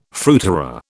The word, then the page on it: fruiterer: British English